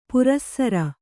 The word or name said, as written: ಪುರಃಸರ